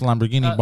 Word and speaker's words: Lamborghini: Because Mr. Lamborghini bought a Ferrari. Dude, just pronounce it correctly, Lamborghini. – I did Lamborghini. – You're saying Guinea. It's Guinea. Lamborghini.